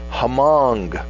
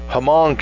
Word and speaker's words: Hmong: How do you pronounce H-M at the beginning of a word without just saying H-M? Hmong community.